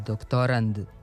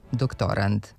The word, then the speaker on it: doktorand: Za onog ko se sprema za doktorski ispit, ko je kandidat za doktorski stepen u nauci, kažemo da je doktorand sa krajnjim D, a ne T. Kako je reč o zvučnim parnjacima, nekad nije sasvim jasno koji smo glas čuli, ali kod akcenta nema dileme, ne bi trebalo da bude na poslednjem slogu.